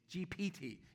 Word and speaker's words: GPT: We've been talking about chat GD. How do you say it? GPD? Something like that. GPT, yes.